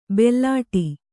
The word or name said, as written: ಬುಲೆಟ್ಟು